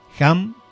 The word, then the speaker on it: HAM: Se escribe H-A-M. Se lee como si fuera una J, la H, ¿no? Es HAM. Se exhala con el mantra SA. S-A-H.